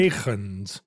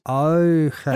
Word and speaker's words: Huygens: See, the tendency is to make Huygens. the H as well. – But right, but it's I think the H is gone. – Eugen. Oh, the H is silent? – Eichens.